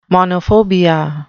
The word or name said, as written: monophobia